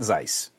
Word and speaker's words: Zeiss: Do you spell that Zeiss? – That's correct. That Zeiss.